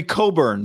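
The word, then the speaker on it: Cockburn: That'd be Coburn. – Coburn. – Kofi yeah. Coburn. Oh, the K is silent. – The K is and the C.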